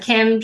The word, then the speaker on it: Kim: Yeah, so Kim, just K-I-M, and then dot, and then Heinz is H-E-I-N-T-Z.